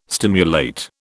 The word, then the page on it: stimulate: British English